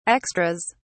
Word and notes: extras: British English

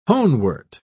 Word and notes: honewort: British English

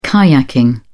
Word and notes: kayaking: British English

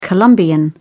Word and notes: colombian: British English

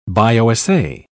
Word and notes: bio-assay: British English

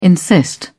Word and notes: insist: British English

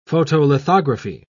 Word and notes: photolithography: British English

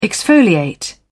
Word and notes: exfoliate: British English

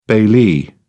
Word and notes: bailee: British English

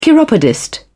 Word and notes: chiropodist: British English

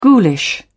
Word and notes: ghoulish: British English

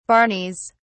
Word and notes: barneys: British English

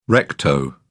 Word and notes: recto: British English

